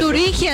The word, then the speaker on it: Turingia: Si lo dije bien, ¿no? Turingia. – Turingia, ¿sabes? Sí, señor, es un sector bien comercial de toda la localidad.